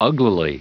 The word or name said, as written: uglily